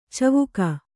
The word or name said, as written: ಚಿವಿಕೆ